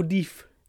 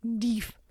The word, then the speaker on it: d'If: At the Chateau d'If. – The Chateau D apostrophe IF. I had to ask you how you pronounce it. It is just D'If. D'If.